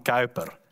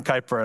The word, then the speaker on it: Kuyper: and ik moet zijn namen op een Hollands uitspreken, Abraham Kuyper and Herman Bavinck. In English, we call them Abraham Kuyper and Herman Bavinck.